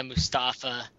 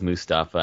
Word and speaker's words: Mustafa: The other thing we wanted to talk about is just, I'm sure you've all heard about the Old Spice guy, Isaiah Mustafa. Is that how you pronounce his last name, Mustafa? – I believe so, yeah.